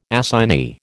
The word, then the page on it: assignee: American English